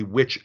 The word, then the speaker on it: wych: And they stumble across inside of a witch elm, which is spelled W-Y-C-H, the remains of a young woman.